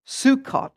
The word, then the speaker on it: Sukkot: And October 3rd is the day before the end of the Feast of Tabernacles, or Sukkot, as it's pronounced.